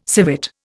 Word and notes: civet: Indian

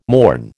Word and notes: mourn: American English

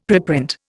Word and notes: preprint: Indian

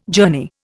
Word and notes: journey: Indian

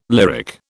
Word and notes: lyric: British English

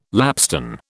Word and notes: lapstone: British English

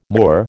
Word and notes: Moor: American English

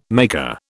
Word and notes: maker: British English

